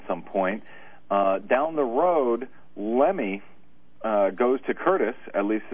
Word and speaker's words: Lemme: Raymond Lemme, actually. – Lemme, okay. – I understand it's pronounced.